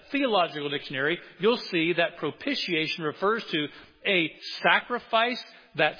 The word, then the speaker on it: propitiation: Well, somebody looked it up and said it's propitiation. Think initiation, right? Initiation. It sounds just like that.